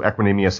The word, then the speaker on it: Equanimeous: Equanimeous St. Brown.